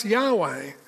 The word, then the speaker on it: Yahweh: The word is literally Yahweh, pronounced Yahweh.